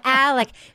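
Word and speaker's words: Alex: No, it's Alec. She says Alik because of her accent, but it's Alec. It's Alik Murdaugh.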